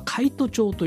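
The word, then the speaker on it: 海津町: そして神奈川県の三浦市なんですけども、海の外の町、海外町というふうに読めなくもない町の名前があってあるんですけど、これは海都町というんだそうですね、読み方的には。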